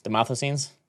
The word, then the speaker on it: Demosthenes: Yeah, Demosthenes.